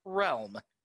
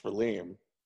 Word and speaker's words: realm: Because wasn't sure how to pronounce the word realm. – I had never heard the word realm spoken. So every time I read it, I thought the word realm was pronounced Relim.